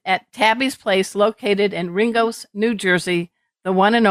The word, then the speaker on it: Ringoes: It's located in, how do you pronounce that, Ringo's? – Ringo's, just like the beetle.